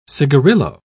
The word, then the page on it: cigarillo: British English